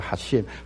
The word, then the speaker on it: Hashem: 是我的犹太老师教我的念法，这个念作 Hashem Tzevaot。什么叫做 Hashem Tzevaot？第一个字叫做 Hashem 就是神，就是主。但是希伯因为实际说你不可以妄称神的名，所以希伯来人就称神的名这四个字。后来有人称什么 Adonai，称亚威亚耶和华，可是正统的希伯来文这个字是念作 Hashem， Hashem 的意思就是名字。